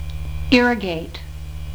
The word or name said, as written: irrigate